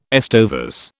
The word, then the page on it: estovers: British English